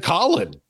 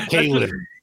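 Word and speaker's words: Khalen: You said Colin. Yeah. It's not Kalen. – Well, because right, a lot of people call him Kalen. The funny thing is the Colin Saunders Twitter handle is Colin, not Kalen.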